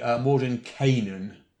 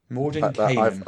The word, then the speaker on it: Mordenkainen: Gygax says the character is patterned after a Finnish wizard. So it's actually Mordenkainen. So I've always said Kainen on that. It's Kainen. Mordenkainen.